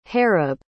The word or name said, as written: harib